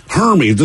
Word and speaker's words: Hermes: Well, Hermes silver. Rhymes with, you know. – What? – Herpes. – That's close. – I mean, it's close. – Hermes. – It's closer to like Burmese, like a Hermes python.